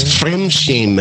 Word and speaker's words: Fremdschämen: No, it's Fremdschämen. It sounds like friend shame, but that's close enough.